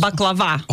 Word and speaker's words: baklava: Okay, so baklava. Mm-hmm. Am I saying it right? First, baklava.